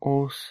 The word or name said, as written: os